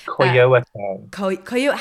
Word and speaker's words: Coyoacán: I am going to look at how to pronounce that, but it's spelled C-O-U-O-C-O-A-N. – Coyoacán. – How do I say it? – Coyoacán. – Ooh, Coyoacán.